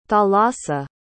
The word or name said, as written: thalassa